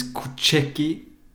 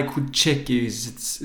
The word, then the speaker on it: Koucheki: My name is Kucheki, K-O-U-C-H-E-K-I, Kucheki, it pronounces Kucheki. – Just like it's written.